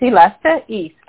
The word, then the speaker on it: Elista Istre: Sure, it's Elista Istre.